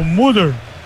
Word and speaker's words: Mulder: Mudo? Não, não é mudo, é o Mulder.